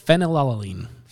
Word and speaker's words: Phenylalanine: Phenylalanine. That can't be the right way to say that. – Phenylalanine. – Phenylalanine. – Yep.